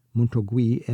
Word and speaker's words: Montorgueil: Montorgueil, and I love Rue Claire and Rue de Martyr, Rue de Rosier.